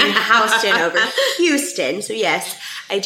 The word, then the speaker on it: Houston: And it is pronounced Houston. – Correct. – Not Houston.